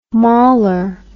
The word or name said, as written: mauler